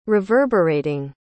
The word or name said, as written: reverberating